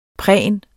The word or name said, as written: pren